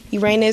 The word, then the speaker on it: Urania: I hear each syllable butchered by a friend in the hallway. And when I was younger, Urania. It became exhausting having to correct them so many times when it seemed so simple to me. For all the mispronunciations, Urania is my name.